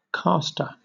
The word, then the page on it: caster: British English